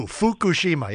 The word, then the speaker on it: Fukushima: It's a U, long U, Fukushima.